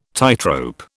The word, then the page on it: tightrope: British English